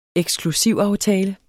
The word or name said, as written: eksklusivaftale